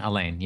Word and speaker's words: Alain: Elaine? – How's it spelled? – A-L-A-I-N. – Elaine, yeah. – Usually Elaine's spelled with an E. – No. It's the French for Alan.